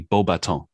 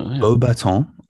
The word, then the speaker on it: Beauxbatons: Well, in French, just to let you know, we don't actually pronounce that S. It'll be Beauxbatons. – Okay. – Yeah. – Beauxbatons.